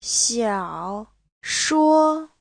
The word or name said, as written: xiao3shuo1